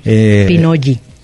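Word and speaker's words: pinolli: Es, uh-huh, una palabra como muchas otras que hablamos aquí que viene del náhuatl y originalmente se escribe pinoli con doble L, uh-huh, en este caso. – Pinoyi. – Así es, Pinoyi.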